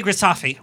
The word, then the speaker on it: Grisafe: And then we have joining us Sami Grisafe, rhymes with coffee. – Yeah, you nailed it.